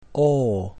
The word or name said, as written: O